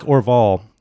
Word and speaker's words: Orval: I know you can't tell us what kind of yeast, but one thing I did want to know, you know, it's like Orval.